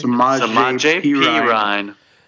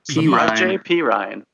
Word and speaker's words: Samaje Perine: Samaje Perine. Samaje Perine.